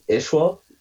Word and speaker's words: Isua: Yeah, this band's called Ishwa, but it's spelled I-S-U-A. – Ishwa.